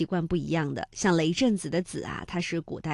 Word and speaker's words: 雷震子: 那之前呢，我们也在节目当中梳理过啊，类似于像孔子、孟子，这个子呢虽然没有实意，但是要保持声调来凸显人名的完整性。那雷震子呢也是如此哈。